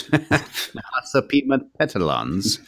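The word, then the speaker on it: Nahasapeemapetilons: Nahasapimapetilons. – Yes. – Nahasapimapetilons.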